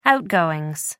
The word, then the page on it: outgoings: American English